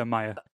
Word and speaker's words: Maya: How do you pronounce it? Is it Maya? – Oh, that's not the part. I thought you were going to ask how you pronounce. – That was good, John. Yeah, Maya.